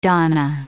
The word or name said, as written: donna